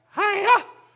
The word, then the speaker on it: Hayah: But that's the verb Hayah, like Hayah. That's actually what it would be like. That's how you pronounce it.